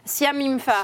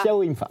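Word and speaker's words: Siao Him Fa: On peut parler peut-être de l'incroyable Adam, j'ai peur d'écorcher son nom. – Siaw Imfa. – Siaw Imfa. – Bravo. – Ciao, Wim Fah.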